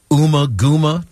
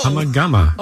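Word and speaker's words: Ummagumma: The album, Uma Guma, did I say that right? – No. – Oh! – I'm a gumma.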